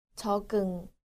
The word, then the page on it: 적응: jeogeung